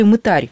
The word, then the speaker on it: мытарь: Отец Александр, а второй участник этой истории, мытарь или мытарь, как в церковно-славянской традиции принято ставить ударение, в русском языке мытарь.